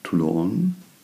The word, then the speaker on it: Toulon: und nicht von Toulon.